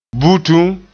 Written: butum

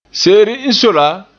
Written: se'ere n soe la

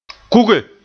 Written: kuge

